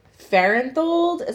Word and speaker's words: Farenthold: When a representative from Texas, Farenthold, is that how we say his name? – Yeah.